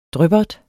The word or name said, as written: dryppert